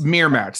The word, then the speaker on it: Miramax: She got an internship at Miramax. I think that's how that's pronounced. – That like Miramax? – Miramax, that's it.